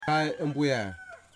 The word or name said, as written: Yimas